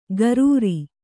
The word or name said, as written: ಗರೂರಿ